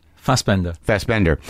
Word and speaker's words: Fassbender: Because, like, you know, when you talk the way you're talking about Fassbinder, does he pronounce it Fassbender or Fassbinder? – Fassbender.